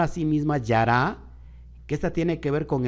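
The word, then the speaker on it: yara (ירה): Y esta viene de la palabra yará Jude Reish Hey, y se, y se, y se pronuncia como yara ¿Y qué significa yara Significa acometer.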